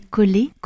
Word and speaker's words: Caulet: Alors ce qui est amusant avec les Collet, Collet ça s'écrit C-A-U-L-E-T, et ça veut dire chou en occitan.